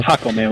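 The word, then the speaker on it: Jácome: Jacome, with an accent on Jacome. The first A. Jacome, yeah.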